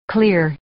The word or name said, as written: clear